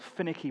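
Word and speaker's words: Finaghy: When I was growing up, I came across one church called Finicky Baptist Church. They weren't finicky. It's just how you pronounce it in Northern Ireland.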